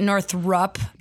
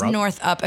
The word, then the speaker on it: Northup: Well, I usually get Northrup, but Northrup. It is Northup.